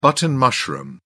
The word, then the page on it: button mushroom: British English